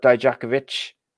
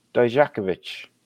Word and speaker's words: Dijakovic: But then also you've got Riddle, you've got Dijakovic, and you've got Chelsea Green apparently going to Raw and SmackDown. – Try to say his name one more time. I gotta hear this. – Dijakovic.